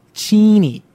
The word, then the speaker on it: Cheney: The town where the actual campus is located is it's just spelled Cheney, which how would you pronounce that? – Cheney? – Yeah. It's pronounced Cheney.